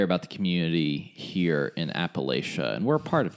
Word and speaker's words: Appalachia: So pro tip, if you want to sound like you're from here, it's Appalachia. No, Appalachia.